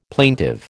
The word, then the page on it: plaintive: American English